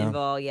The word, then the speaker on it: Engvall: Engvall, yeah.